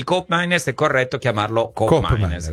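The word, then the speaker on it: Koopmeiners: Qui parliamo fiammingo, che è l'olandese, per la cronaca colgo l'occasione per dire che di Cop Miners, la doppia O di Cop Miners invece si legge U.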